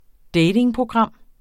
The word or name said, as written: datingprogram